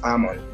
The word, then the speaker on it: Amon: The pronunciation, you know, it has variant pronunciation, but one of it is Amon.